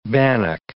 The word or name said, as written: bannock